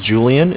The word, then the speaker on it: Julian: Okay, my name is Julian. It's J-U-L-I-A-N.